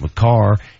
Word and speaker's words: Makar: I heard Moj pronounce it Makar. I said it's Makar, and I saw it in front of me, and I don't know what happened.